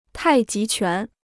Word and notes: 太极拳: tài jí quán

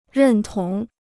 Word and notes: 认同: rèn tóng

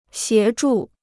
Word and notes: 协助: xié zhù

